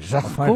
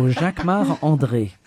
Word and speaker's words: Jacquemart-André: You say it like a Frenchman. Jacquemart-André. – Of course, because I'm French. So Jacquemart-André. – Say it so we can understand it. – Jacquemart-André. – Oh, goodness. – See, if you lived in the States for 20 years, you could speak like one of us Yankees. – Butchering your French words.